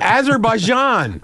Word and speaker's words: Azerbaijan: Azerbaijan. – Azerbaijan, whatever.